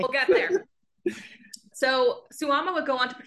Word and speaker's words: Suwama: Yeah, they took out the ma and added in the, I'd probably pronounce it like as Mao, like on its own, but in the whole, it's the same pronunciation, Suwama, but it means like demon instead.